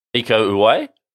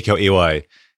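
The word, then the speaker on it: Iko Uwais: So he met Iko, do you pronounce it Iwas? – Iko Uwai? – Iko Uwai.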